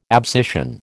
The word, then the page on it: abscission: American English